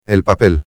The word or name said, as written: el papel